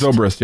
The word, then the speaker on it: Zobrist: But I still actually don't know entirely how to pronounce his last name, but I think it's Zobrist. – Zobrist, yeah.